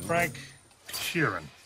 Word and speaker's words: Frank Sheeran: Frank Sheeran. I said that right? – Yeah, you said right.